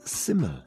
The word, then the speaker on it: Syml: The guy's name is pronounced Syml. S-Y-M-L.